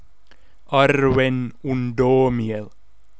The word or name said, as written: Arwen Undomiel